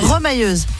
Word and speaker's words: remailleuse: Est rémailleuse. C'est ce que c'est? – Rémailleuse. – C'est ça, avec les mailles. – Remailleuse. – On dit, ça se prononce pas comme ça? Remailleuse? – Remailleuse, oui. – Ah, remailleuse, pardon.